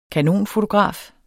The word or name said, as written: kanonfotograf